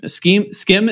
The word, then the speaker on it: Schim: Is it pronounced Skim? – Oh, S-C-H-I-M? – Yeah, and I don't even really know how to explain this game, but, like, you kind of have to jump.